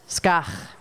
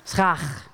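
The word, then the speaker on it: Skach (סכך): And as a matter of fact, the stuff that you put on the roof is the most difficult thing to pronounce in Hebrew. It's called skach. Can you say that? Skach. Skach. Can you say it, Cantor? Schach, right. I know, it's hard to say.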